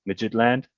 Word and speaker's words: Midtjylland: I think I'm pronouncing that right. Midtjylland.